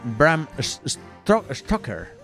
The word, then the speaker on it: Bram Stoker: Bram, Bram Stoker. Eh, sí, sí, vamos, que la has pronunciado súper bien.